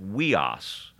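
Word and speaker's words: weos (υἱός): And then the next one is a Y, and it's funny, in Greek it's pronounced weos. It's U-I-O-S with a breathing sound, so it's weos, they call it.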